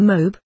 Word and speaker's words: Moab: It is said that Naomi, her and her husband and her two sons went into the land of Moab, pronounced almost in one syllable, to earn a living.